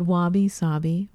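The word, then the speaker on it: wabi-sabi: So going back to the Wabi Sabi and how we found out what what Wabi Sabi rhymes with Bobby actually means.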